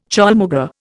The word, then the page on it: chaulmoogra: Indian